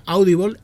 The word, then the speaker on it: Audible: Si te gusta escuchar podcast, Audible, que se pronuncia así, se pronuncia Audible, es vuestra plataforma.